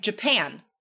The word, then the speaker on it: Japan: Japan. That's a noun, but we stress the second syllable.